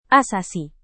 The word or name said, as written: asasi